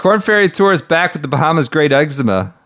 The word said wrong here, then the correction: Exuma: Exuma. People always make fun of my pronunciation.